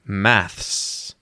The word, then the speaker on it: maths: So, it's the short a vowel sound in Australian English. A, like in the word cat or bat. Cat or bat. Maths.